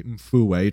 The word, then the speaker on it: Mfuwe: Unfortunately for them, the Mfuwe Lodge, I think I'm pronouncing that correctly, Mfuwe, it's M-F-U-W-E, Mfuwe Lodge in Zambia, was constructed along their migration route due to the mangoes.